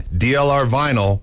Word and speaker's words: DLR vinyl: Remember, for fencing, DLR vinyl rhymes with final.